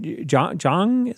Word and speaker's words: Jeong: This is directed by Joe Carnahan, stars Frank Grillo, Mel Gibson, Naomi Watts, Ken Jeong, is that how you pronounce his name, is in there from Community? – Yep.